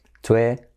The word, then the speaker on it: Twitter: Muy bien, número 4 en esta lista, que sería lo que decimos en español Twitter, Twitter, pero en inglés fijaos cómo se dice Twitter. Tenemos la I como E, Twitter, la T como R suave y luego la letra clave R, Twitter.